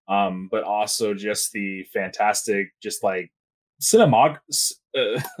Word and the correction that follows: cinematography: uh, cinematography that what I'm saying that wrong.